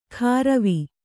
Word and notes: ಕಾರವಿ: Kannada

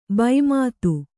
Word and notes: ಭೂಮಿತಿ: Kannada

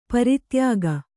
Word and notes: ಪರಿತ್ಯಾಗ: pari tyāga

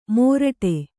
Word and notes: ಮೊರಟೆ: Kannada

